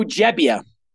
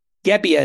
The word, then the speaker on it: Gebbia: Joe Gebbia, who people thought he's with a G. Gebbia is how people have pronounced it, but it's actually Gebbia.